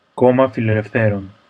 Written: Komma Fileleftheron

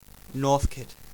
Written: Northcote, Victoria

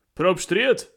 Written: Probstried

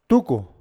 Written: Tuku

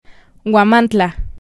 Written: Huamantla

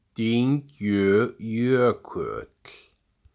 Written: Dyngjujökull

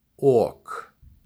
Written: Ok

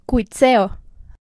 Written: Cuitzeo

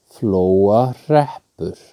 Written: Flóahreppur

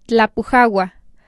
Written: Tlapujahua

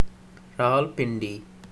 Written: Rawalpindi

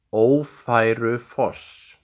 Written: Ófærufoss

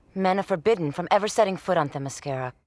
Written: Themyscira

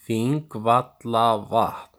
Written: Þingvallavatn

